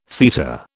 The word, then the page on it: theta: British English